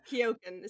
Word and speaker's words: Keoghan: it's Keoghan is how he pronounces it.